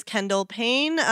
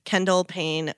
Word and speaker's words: Kendall Payne: My name is Kendall Payne, spelled like the Jenner, Kendall, and then P-A-Y-N-E. And then cross-platform, I'm at Kendall Payne with two E's at the end.